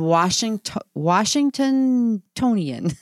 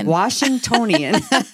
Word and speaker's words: Washingtonian: and Washingtonian. I'm not saying that right. – Washingtonian Top Doc in 2020 and 2021.